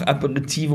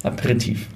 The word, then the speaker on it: Aperitif: Äh, äh, ganz kurz, bevor du Cheers sagst, kannst du noch Aperitive oder Aperitif noch richtig aussprechen? – Jetzt Aperitif? Aperitif. – Aperitif. Aperitif. Aperitif. Aperitif. Dankeschön.